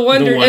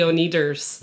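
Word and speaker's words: Oneders: It's the Wonders. And originally it's spelled O-N-E. – Because they're trying to do a pun sort of name like the Beatles with Beat. Let's call it Wonders, but it's O-N-E. What's the problem with that, though? – Well, everybody mispronounces as the O-Needers.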